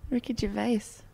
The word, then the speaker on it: Gervais: Do you say, how do you say the creator of The Office's name? – Ricky Gervais. – Yeah, okay. How would you say that? – Ricky Gervais. – Yeah, that's it.